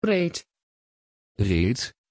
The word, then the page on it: reed: Dutch